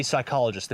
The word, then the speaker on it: psychologist: What are you, a psychologist? – That would be psychologist. The P at the start is silent.